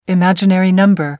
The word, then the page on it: imaginary number: British English